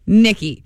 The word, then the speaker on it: Nikki: I was told that it is how a lady of the evening would spell Nikki.